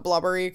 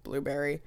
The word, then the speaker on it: Blubrry: The provider we ended up going with is, we call it Blubbery because it's spelled like Blubrry, but it is actually, I think, Blueberry.